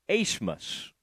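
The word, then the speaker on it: Abmas: It's A-B-M-A-S, but it's pronounced Asemus.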